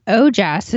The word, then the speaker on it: ojas: And ojas is spelled O-J-A-S.